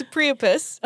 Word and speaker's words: Priapus: I don't know how Priapus? – To pronounce it. – As like platypus? – Usual. Yes. Minus the pla. – Minus the bill. – Yeah. It's Priapus.